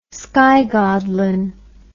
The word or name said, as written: skygodlin